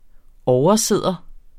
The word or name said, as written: oversidder